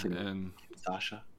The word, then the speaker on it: Kinshasa: Yeah, Nakamura hit him with the Kinshasa. Is that how you say it? – Kinshasa? – Yeah. – Kinshasa.